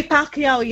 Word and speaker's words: Pacquiao: Manny Pacquiao, yeah, you said that right.